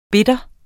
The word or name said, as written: bitter